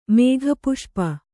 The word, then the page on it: ಮೇಘಪುಷ್ಪ: kannada